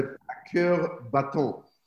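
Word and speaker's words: À cœur battant: And again, excuse my French, but A Cœur Battant, that's the right pronunciation.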